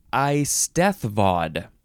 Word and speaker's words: eisteddfod: Okay, the first word in this episode is eisteddfod. I think that's how you say it. Eisteddfod. You got to say that hard TH. Eisteddfod. You could also say eisteddfod. It is spelled E-I-S-T-E-D-D-F-O-D.